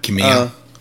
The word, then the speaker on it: Kamiya: Speaking of people who left companies that probably have a bigger story that we'll learn later, Hideki, and I'm going to butcher this, Kamiya? – That's how I would pronounce it. Hideki Kamiya.